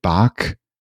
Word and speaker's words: Bach: Roth and Gevrich Slowly, and I think David Bach, I think is how you pronounce his last name.